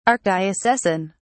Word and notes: archdiocesan: British English

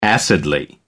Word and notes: acidly: American English

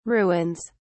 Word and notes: ruins: British English

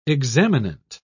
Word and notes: examinant: British English

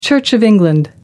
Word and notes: church of england: American English

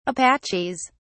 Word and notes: apaches: British English

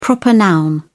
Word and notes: proper noun: British English

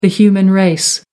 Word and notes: human race: American English

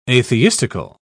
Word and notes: atheistical: British English

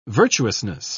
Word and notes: virtuousness: British English

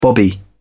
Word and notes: bobby: British English

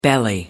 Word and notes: belly: American English

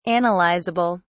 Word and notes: analysable: British English

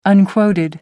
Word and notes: unquoted: American English

